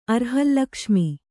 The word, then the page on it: ಅರ್ಹಲ್ಲಕ್ಷ್ಮಿ: Kannada